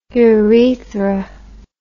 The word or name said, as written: urethr